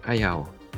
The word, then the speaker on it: Hayao: Ayao. – Hayao.